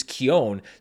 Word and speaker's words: Keoghan: kind of the more traditional Irish Ashway, which is Keown. So I started saying Barry Keown, but then I found a more recent interview where he says, actually, no, it's Barry Keoghan.